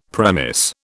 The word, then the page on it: premise: British English